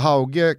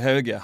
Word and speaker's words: Hauge: Alla i Norge säger Hauge. Han sa själv Hauge. Han tog en Kolasinac med uttal i sitt eget namn fel, för att eventuellt göra det mer internationellt. Men han heter Jens Peter Hauge och ingenting annat.